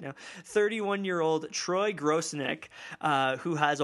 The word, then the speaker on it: Grosenick: Did you say Grosinek? – Grosinek? Grosinek? Grosinek? – No D. No D? – No. No D. No D. No gross dick.